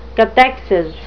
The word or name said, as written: cathexis